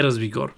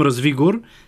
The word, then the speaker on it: Развигор: Разбира се, Балабанов е водещия, но на този вестник, който проучих и ми казаха, че се нарича Развигор, а не Развигор. Оказва се, Виктор Топалов, нашия колега, който също прави това предаване, е намерил бележки на господин Балабанов, където е отбелязано изрично той къде иска да се слага ударението. Така че аз ще го наричам Развигор.